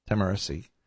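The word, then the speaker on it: Tahmooressi: We started in our last program talking about Sergeant Andrew Tamarisi, but they pronounced it for us. Tamarisi, who is a Marine who is in, reportedly right now, in jail in Mexico because he took a wrong turn.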